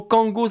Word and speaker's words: Qango: Je veux parler de Kango. Kango, ça s'écrit Q-A-N-G-O.